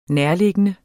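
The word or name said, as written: NÆRTLIGGENDE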